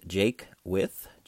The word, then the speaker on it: Jaquith: My name is Ken Jaquith. It's pronounced Jaquith, J-A-Q-U-I-T-H.